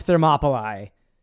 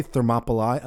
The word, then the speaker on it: Thermopylae: So you say Thermopylae. I've always said Thermopylae. I'm also not intelligent. So from what Latin class AE, I remember being pronounced I.